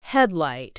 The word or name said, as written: headlight